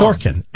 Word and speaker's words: Sorkin: Sorkin.